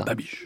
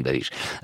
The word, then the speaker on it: Babiš: Babiš. – Ça s'écrit B-A-B-I-S, on dit Babiš.